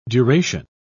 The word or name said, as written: duration